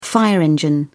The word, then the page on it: fire engine: British English